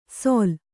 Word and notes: ಸೊಲ್: Kannada

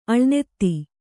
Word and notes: ಅಳಿನೆತ್ತಿ: aḷinetti

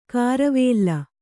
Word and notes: ಕಾರವೇಲ್ಲ: kāravēlla

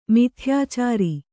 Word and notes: ಮಿಥ್ಯಾಚಾರ: mithyācāra

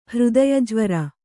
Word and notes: ಹೃದಯಜ್ವರ: Kannada

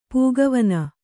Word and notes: ಪಕ್ಕೆವನೆ: pakkevane

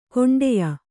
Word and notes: ಕೊಂಡೆಯ: koṇḍeya

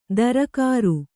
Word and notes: ದರಕಾರು: darakāru

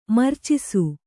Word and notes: ಮೂರ್ಛಿಸು: mūrchisi

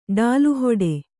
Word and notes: ಡಾಲುಹೊಡೆ: Kannada

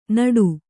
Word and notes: ನಟ್ಟ: Kannada

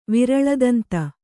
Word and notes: ವಿರಳದಂತ: viraḷa danta

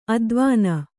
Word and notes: ಅದ್ವಾನ: advāna

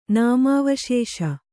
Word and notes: ನಾಮಾವಶೇಷ: nāmāvaśeṣa

